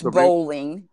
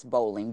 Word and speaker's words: Bolling: Would say it's Bolling. Yes, Bolling. B-O-L-L-I-N-G.